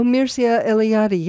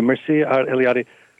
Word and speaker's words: Mircea Eliade: There's a wonderful historian, a Romanian historian of religion, the sacred, and I'm going to mispronounce his name, Marcia Eliade. – Oh, Mircea Eliade, yes. – Thank you, Mircea Eliade, who says that a dwelling contains the world.